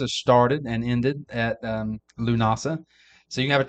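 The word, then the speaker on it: Lughnasadh: The third is Lunasa. It's spelled Lughnasad, but it's pronounced Lunasa.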